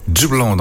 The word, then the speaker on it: Dublon: qui s'appelle Dublon. Donc, c'est lui... Dublin, ben ça s'écrit avec un O, finalement c'est pas Dublin comme en Irlande, et il nous propose des titres, des extraits d'albums pour finalement bientôt sortir l'album complet.